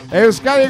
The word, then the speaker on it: Euskadi: Ah, no. Sí, lo he dicho bien. Euskadi Goitarra desde Vitoria-Gasteiz, Jorge Benítez.